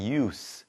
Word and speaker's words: use: 그리고 한 가지 더 알려드리면 동사일 때는 발음이 use, 이렇게 z 발음이 납니다. 하지만 명사일 때는 use, s 발음이 난다는 거. 참고하시기 바랍니다.